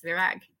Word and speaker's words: Virág: I want to make sure I'm pronouncing your name correctly. Did I say it right? – You really nailed it. Yes, Virág.